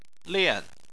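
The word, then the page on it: lian: tone 4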